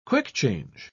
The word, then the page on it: quick-change: British English